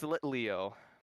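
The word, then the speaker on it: Litleo: Little Leo. – Little Leo? Yeah. – I definitely said it wrong. Wait. Oh, Pyroar. Pyroar is the Pokemon thinking of. – What is Little Leo? – It's Lit Leo.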